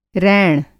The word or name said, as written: रैण